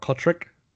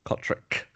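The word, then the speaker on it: Cotric: At fullback, it's Dallin Watene-Zelezniak, Nick Kotrick, which is the way the commentators are starting to pronounce it now, with a Q sound at the end. Nick Kotrick. He's asked for that. Not Kotrick. Kotrick.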